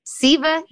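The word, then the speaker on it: Ceva: You can purchase it online at Think, T-H-I-N-K, Siva D, C-E-V-A-D. – Siva, which rhymes with Eva.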